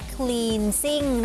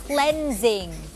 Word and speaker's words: cleansing: ไม่ได้อ่านออกเสียงว่า cleansing นะคะแต่อ่านออกเสียงว่า cleansing คำนี้มาจากคำว่า cleanse